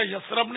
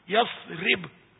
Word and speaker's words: یثرب: یثرب اصل تلفظ کا یثرب نہیں ہے، یثرب.